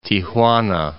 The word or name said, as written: Tijuana